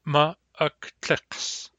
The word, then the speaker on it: ma'aktaqs: This word is a challenge to pronounce, so let's break it down by syllables. Ma. Ak. Tlaqs. Ma'aktaqs.